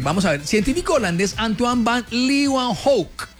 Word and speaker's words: Antoine van Leeuwenhoek: Bueno, don Pipe, mire, un día como hoy de 1683, el científico holandés Antoine van Leeuwenhoek. – ¿Cómo es? Sí. – Antoine van Leeuwenhoek. Es que así se pronuncia. ¿Usted por qué se burla de mí? Estoy pronunciando en holandés, Pipe.